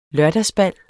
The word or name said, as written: lørdagsbal